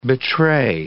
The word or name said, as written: betray